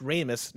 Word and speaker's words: Ramis: Harold Ramis's name is pronounced Ramis, not Ramis.